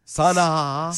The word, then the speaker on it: Sanaa: Sanaa Lathan. It's S-A-N-A-A.